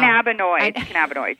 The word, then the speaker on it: cannabinoid: Cannabinoid? Is that how you say it? Cannabis? – Oh, my gosh. – It's like all the rage now. – Cannabinoids.